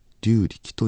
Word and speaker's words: 龍力: 想像上の動物の龍に力と書いて龍力と読みます。龍は雨を降らせると言います。総じて自然ということなのでしょう。自然の働きというものは私たちの想像をはるかに超える、そういう意味で龍力不思議と言います。